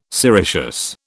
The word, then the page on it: sericeous: British English